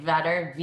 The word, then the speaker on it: Vetter: It's Amy, A-M-Y, Vetter, V-E-T-T-E-R. It is not Eddie Vetter. That's two D's. So mine is two T's.